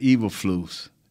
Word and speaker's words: Eberflus: More importantly, it's Matt Eberflus.